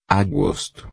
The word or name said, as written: agosto